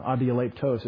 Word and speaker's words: adialeptos: It's adialeptos. It's the same word. I keep saying it wrong. Adialeptos. It's an omega.